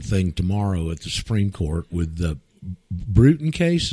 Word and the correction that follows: Brunson: Is that his name? – Brunson. – Brunson. I never can get his name right. Brunson. – Yeah, like Brunson, yeah. Like a Bunsen burner, a Brunson burner.